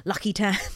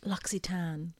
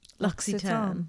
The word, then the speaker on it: L'Occitane: used to call it Lucky Tan. oh yeah, I never I would always be afraid to pronounce it. And uh, yeah, the girl I lived with, she was like, It's L'Occitane.